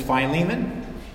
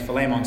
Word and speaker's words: Philemon: We've grown up referring to him as Philemon. The correct pronunciation of his name is Philemon. So even though he's not here to be offended, for our purposes this morning, we'll use his correct Greek pronunciation.